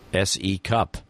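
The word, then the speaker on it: S.E. Cupp: Now, what is her name actually, if you were to pronounce it? – S-E Cup. – S-E Cup. Like a C cup.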